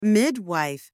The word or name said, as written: midwife